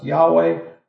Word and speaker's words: Yahweh: sometimes pronounced Yahweh, the personal name for God for rabbinic readers was thought to represent God's merciful character.